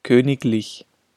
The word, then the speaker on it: königlich: Ausnahme. Wenn die nachfolgende Silbe die Silbe lich ist, wird G gesprochen. Lediglich, königlich.